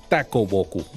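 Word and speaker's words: Takuboku: el escritor japonés Ishikawa Tokuboku. Lo dije bien, ¿verdad? Takoboku. Muy bien.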